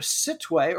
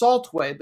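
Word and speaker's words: Sittwe: They play out of the city of Sitwe, or actually it's pronounced Saltway, but it looks like Sitwe, in the Rakhine State, for which their club is named.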